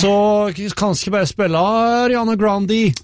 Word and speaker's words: Grande: Hun jo til italiensk opphav, så det ikke feil å si Ariana Grande. – Mm. – Men hun selv sier selv at hun gjerne vil uttale det Grande. – Oh, ja. – Så okay. Ariana Grande. – Åh, oh, ok, mm-hmm. grann.